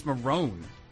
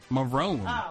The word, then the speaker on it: Morrone: It's spelled like Michelle, and it's Marone. M-O-R-R-O-N-E. Marone.